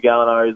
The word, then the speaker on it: Gallinari: I apologize for my pronunciation the first time around, folks, but Gallinari is what I'm going with.